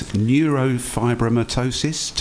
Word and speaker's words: neurofibromatosis: Now, Katie was just 18 months old when she was diagnosed with, and forgive me if I say this wrong, but it's neurofibromatosis type 1. – That's correct, yes.